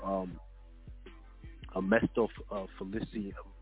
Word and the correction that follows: Mephistophelian: excuse me, let me make sure I'm, pronouncing it right, a, a Mephistophelian bargain, right?